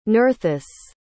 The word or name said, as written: Nerthus